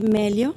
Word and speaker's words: Meglio: Se escribe Meglio, pero el ajeno suena. – Para efectos... Meglio Ordóñez, el pelotero. – Bueno, creo que ese es Maglio, ¿no? – Mira es solidario. Se ríe en mi tontería. – Ajá. Sí, entonces la G no suena para efectos de pronunciarla, pero sí es importante que la escriban para poder encontrar las páginas y toda la información.